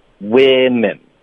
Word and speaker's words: women: 然后复数的，它的发音是 we， a 的音，women。